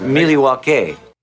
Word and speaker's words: Milwaukee: Actually, it's pronounced Milwaukee.